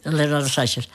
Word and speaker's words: Leonardo Sciascia: Leonardo Sciascia.